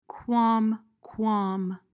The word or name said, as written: qualm